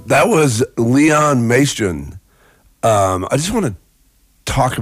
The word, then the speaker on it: Majcen: It's Leon Maestron. It's spelled M-A-J-C-E-N.